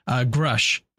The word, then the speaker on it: Grusch: And Grush? I'm going to butcher this. Grush. OK, thank you, Jimmy. Grush.